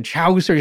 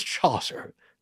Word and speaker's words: Chaucer: I said Chaucer. He's like, it's Chaucer. Excuse me, sir. It's Chaucer.